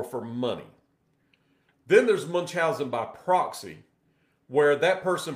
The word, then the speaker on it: Munchausen: Some people will pronounce it different ways, but it's Munchausen, whatever you want to call it.